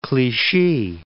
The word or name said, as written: Clichy